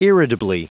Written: irritably

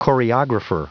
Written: choreographer

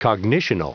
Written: cognitional

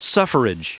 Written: suffrage

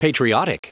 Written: patriotic

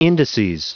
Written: indices

